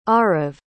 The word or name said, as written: Arav